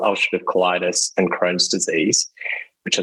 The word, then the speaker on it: ulcerative colitis: You can say it for ulcerative colitis.